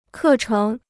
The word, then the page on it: 课程: kè chéng